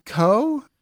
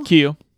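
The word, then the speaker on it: Keough: So yeah, Riley Coe, is that how you say her name? Cow? – Keo.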